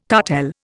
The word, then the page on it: cartel: Indian